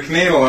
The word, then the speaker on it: Knoodle: So, How's the wallaby and the knoodles doing? – Well, the knoodle I'm almost done. – Yeah. did I say that right? Knoodle? – Knoodle, yeah. Yeah, absolutely.